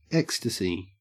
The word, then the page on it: ecstasy: Australian English